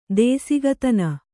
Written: ದೇಸಿಗತನ

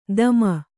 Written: ಧೂಮ

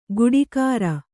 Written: ಗುಡಿಗಾರ

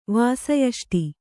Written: ವಾಸಯಷ್ಟಿ

